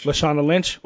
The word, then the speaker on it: Lashana Lynch: Lashana Lynch? – Lashana Lynch.